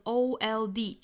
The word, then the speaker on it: auld: 首先第一个词就是 old, 就把它发成 old 就可以了哈 old. 后面这个 lan 里面的这个元音呢是梅花 a 的音，所以我们发成 lan 最后是双元音 i zine 再加鼻辅音 zine auld lang syne auld lang syne。好，这句话英音我们再非常慢速的读一下，我读一遍大家读一遍。 Should old